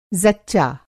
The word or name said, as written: ज़च्चा